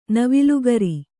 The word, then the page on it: ನವಿಲುಗರಿ: navilu gari; Kannada